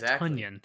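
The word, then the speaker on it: Tonyan: Yeah, Tunyon. That's how you pronounce it. – Tunyon, like onion. – Tunyon. Oh, I like it like Paul Bunyan.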